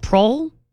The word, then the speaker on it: Proehl: Proyle? – It says rhymes with coil or boil. Proyle.